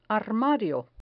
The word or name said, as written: armario